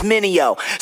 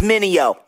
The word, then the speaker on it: Minio: Everybody make mistakes like VJs, trying to say my name, it's Minio. Say it with me, Minio.